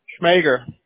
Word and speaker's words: Schmoeger: Schmager. – Okay, spell that for me, please. – S-C-H-M-O-E-G-E-R.